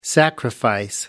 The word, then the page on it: sacrifice: American English